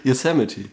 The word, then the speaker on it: Yosemite: Yosemite, ja.